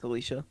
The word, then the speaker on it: Alicia: Is it Alicia or Alicia? – Yeah, it's Alicia.